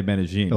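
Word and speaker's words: Medellín: Also, there was a restaurant in Medellin. I say Medellin. – Oh! I had to say it right.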